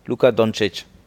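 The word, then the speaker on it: Luka Dončić: Luka Doncic. Ah, okay. Richtig ausgesprochen, ne? – Ja. – Luka Doncic.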